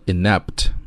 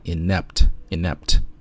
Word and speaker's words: inept: 首先第一个词呢叫做 inept, 你注意读音的时候啊 n 和那个 a 音节是连读的 ,inept,inept。